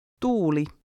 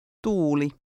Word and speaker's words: tuuli: The double U is pronounced like the U sound in the English word boot. Try to read number 12. – Tuuli.